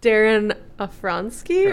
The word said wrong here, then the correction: Aronofsky: Or Aronofsky.